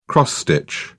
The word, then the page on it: cross stitch: British English